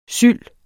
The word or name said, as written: syld